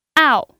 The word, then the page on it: ao: 4